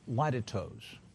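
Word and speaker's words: litotes: Well, what Paul uses here is a leitetos, which it could be pronounced better, but that's how the dictionary pronounces it, L-I-T-I-T-O-E-S.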